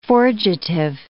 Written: forgetive

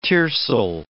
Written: tiercel

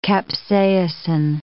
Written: capsaicin

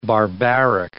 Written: barbaric

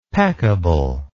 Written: peccable

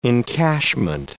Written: encashment

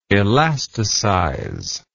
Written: elasticize

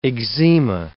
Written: eczema

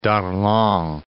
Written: Darlan

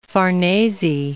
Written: Farnese

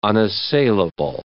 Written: unassailable

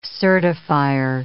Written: certifier

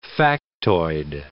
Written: factoid

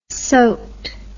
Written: soaked

